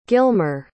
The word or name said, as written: gilmer